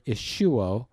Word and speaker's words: ischuo: It's the word ischuo. It's spelled I-S-C-H-U-O, ischuo.